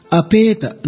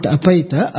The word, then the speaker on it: apeta: If you see the wordings, apeta, not apaita, apeta, vita, the ta syllable gets downward intonation, anudatta, cha goes upward, sarpatatah, pa again comes down, the last syllable ta again goes up.